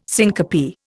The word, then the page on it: syncope: Indian